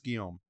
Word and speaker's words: Guillaume: Marla Gibbs, Eddie Griffin, Robert Guillaume, Guillaume, I've been saying that wrong for years. – Yeah, it's Guillaume.